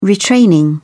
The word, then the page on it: retraining: British English